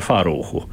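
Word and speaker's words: Fərrux: Eh, man nav tagad prātā kāda, nu, šķiet, ka Krievi, eh, Krievi to raksta nu, un izrunā kā farūg, un viņiem tika mhm. aizrādīts, ka, lūk, azerbaidžāņu valodā, kas ir vienīgā leģitīmi lietojamā šajā teritorijā, kas ir Azerbaidžānas teritorija, būtu jāsauc to korekti par farūhu.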